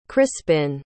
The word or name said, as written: crispin